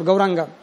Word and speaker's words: Gauranga: they accept the four syllables Gau, Ra, An and Ga. These are the four syllables. Gaur, Gau, Ra, An, Ga. For Gauranga.